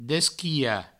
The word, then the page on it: desquilhar: Provençal; desquiha